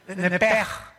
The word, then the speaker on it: nepesh: Así como suena, N-E-P-E-S-H. Nepesh. La palabra, la letra o las letras SH en hebreo se pronuncian en hebreo como J. Entonces se pronuncia nepesh. Y los judíos, como los alemanes, ponen mucho énfasis en el no voy a hacer otra cosa, ¿verdad? No se asusten. Nepej.